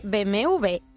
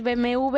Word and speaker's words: BMW: La marca tiene mucha culpa de que nosotros digamos BMW en vez de BMW doble. Claro. ¿Pero por qué? Bueno, pues porque la W en alemán se pronuncia así. – BMW. – Se pronuncia como la V.